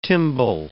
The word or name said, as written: timbal